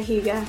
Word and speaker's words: Higa: Maya Higa.